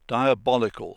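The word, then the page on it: diabolical: British English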